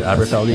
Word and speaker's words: Aberfeldy: Aberfeldy? Is that how you pronounce it? – Aberfeldy? That's, that's right.